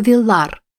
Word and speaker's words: Виллар: Она впустила его с условием, что название будет изменено на «Шато Виллар» с ударением на 2-м слоге.